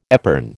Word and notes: epergne: American English

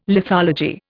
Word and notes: lithology: Indian

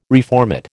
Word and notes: reformat: American English

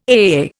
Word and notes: AAA: Indian

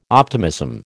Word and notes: optimism: American English

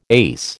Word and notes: ace: American English

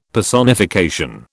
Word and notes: Personification: British English

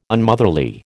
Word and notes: Unmotherly: American English

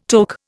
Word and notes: toque: Indian

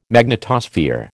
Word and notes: magnetosphere: American English

